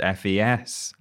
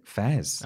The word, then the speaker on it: FES: I have just learned from you that it's just pronounced FES. I had to talk about that game in a list. And oh, yeah. – I Googled it. I was like, is this FES? Mm. And loads of people on YouTube are calling it Fez.